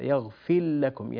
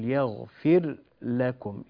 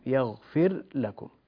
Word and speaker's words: يغفر لكم: مثل يغفر لكم، يغفر لكم، كثير منا يقرأ هكذا يغفر لكم، يعني يدغم الراء في اللام. هنا لا، لابد أن نبين الراء، نقول يغفر لكم يغفر لكم.